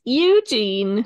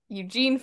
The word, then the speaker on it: Eugene: Just hate how she says Eugene. – The emphasis on the wrong syllable.